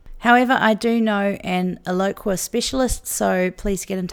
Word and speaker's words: Eloqua: Eloqua, which is spelt E-L-O-Q-U-A,